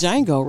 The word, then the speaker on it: Django: The Django, and that's the D is silent. – This yes. Django, right? – Yeah, the D is silent.